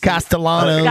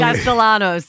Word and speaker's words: Castellanos: Castellanos. – Castellanos.